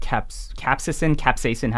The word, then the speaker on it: capsaicin: So you mentioned how certain foods like capsaicin, how do you pronounce it? – Capsaicin.